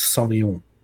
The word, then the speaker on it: Sauvignon: I would just like to do one correction on pronunciation. It's Sauvignon.